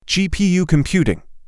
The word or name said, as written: GPU computing